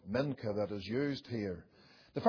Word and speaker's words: mincha: It is pronounced mincha, and it simply means an offering or a present.